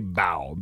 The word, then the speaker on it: bao: How do you say bao? – Bao. – Is it B-A-O? – I think it's bao.